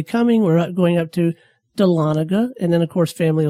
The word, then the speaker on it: Dahlonega: It's Dahlonega. I always thought it was Dahlonega, like Talladega. – Right. And it's not.